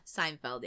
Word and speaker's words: Seinfeld: And I was like, I used to call it Seinfeld. And I was like, you're saying that right? Seinfeld, right? Seinfeld. – Seinfeld, yeah.